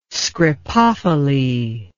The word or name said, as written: scripophily